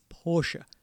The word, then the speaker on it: Porsche: So I think this is Jeff's way of saying I've been saying it wrong. It's Porsche.